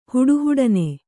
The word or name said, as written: ಹುಡುಹುಡನೆ